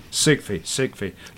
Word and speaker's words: Sigfi: Sigfi. – Like Sigmund Freud, only without the mund.